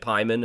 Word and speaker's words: Paimon: I'm, I'm going to say King Paimon because that's how they say it at the end of the film, but uh, it can also be said Pyman, as you pointed out before we started this interview.